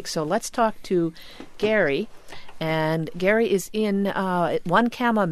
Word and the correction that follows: Onekama: Michigan. Is that correct, Gary? – Onekama. – I wasn't even close. Sorry about that. – That's okay. No one seems to pronounce it right.